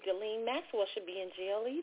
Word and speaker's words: Ghislaine Maxwell: I just happened to be watching a documentary on, I'm going to say her name right, finally, Ghislaine Maxwell.